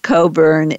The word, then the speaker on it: Cockburn: And I want to emphasize that the spelling of your name, even though it's pronounced Coburn, is spelled C-O-C-K-B-U-R-N. Thank you so much, and I wish you the very best, Darren.